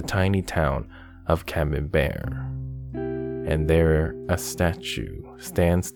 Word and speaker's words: Camembert: Since Camembert rhymes with beware,